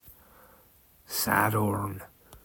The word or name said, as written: Sadorn